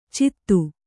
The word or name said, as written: ಚಿತ್ತು